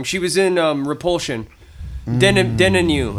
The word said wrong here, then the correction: Deneuve: Is it Catherine Deneuve? – Yes, Deneuve. Yeah.